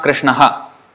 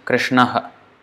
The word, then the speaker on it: Krishnaha: It's not Krishnaha, that is H-A, Krishnaha. The correct way of pronunciation is Krishnaha, Krishnaha. So you stop it there, it's like, that is Visarga.